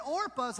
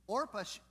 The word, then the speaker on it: Orpah: Now, I want you to hear, like, Orpah, I always want to call it Oprah. I keep saying it wrong.